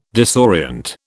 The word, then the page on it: disorient: British English